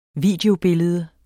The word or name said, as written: videobillede